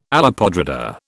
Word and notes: olla podrida: British English

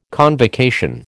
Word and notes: convocation: American English

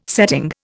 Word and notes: setting: Indian

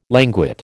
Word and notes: languid: American English

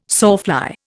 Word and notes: sawfly: Indian

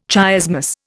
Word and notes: chiasmus: Indian

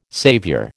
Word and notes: saviour: American English

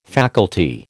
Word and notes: faculty: American English

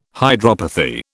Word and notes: hydropathy: British English

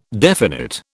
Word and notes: definite: British English

